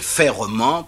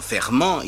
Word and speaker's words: ferrement: je dis bien ferrement parce que beaucoup de gens se trompent et prononcent simplement ferment. Il s'agit bien de ferrement. Euh, le mot fer, c'est la racine.